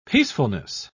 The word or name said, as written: peacefulness